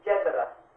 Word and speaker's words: Jedara: Jethara. – Did you guys hear that? – It's Jethara, J-E-D-A-R-A, and you roll the R, Jethara.